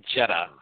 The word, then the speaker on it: Jeddah: It was in Jenna? – It's Jeddah, I believe.